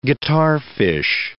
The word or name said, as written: guitarfish